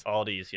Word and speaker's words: Aldi: Did you hear how she pronounced it? – What? – Aldi's. – Aldi's, yes. – There's an S on the end of Correct. – It in Pittsburgh. – Correct. – Everywhere else, it's just Aldi.